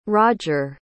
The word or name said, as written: rodger